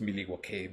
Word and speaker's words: Milwaukee: De hecho, la pronunciación correcta es Milwaukee. Viene viene de las tribus, no sé qué, y significa el río que cruza la frontera.